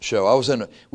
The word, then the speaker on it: shew: Anyway, but if you look that word up, S-H-E-W is actually pronounced show. We were doing a Bible baseball one time up at the Pot of Gold, and one of the kids was doing his memory, and he pronounced the word show. And the guy said, "Nope, you're down." And I'm like, don't kick him out for that. He pronounced it right. If you look it up in the dictionary, you can pronounce it shoe or show.